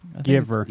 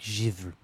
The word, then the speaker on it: Givre: Black Giver. – I think it's just Giver. – It's not, though. You just want to say Giver? It's like Black Givre. Chevre. It's like a soft cheese.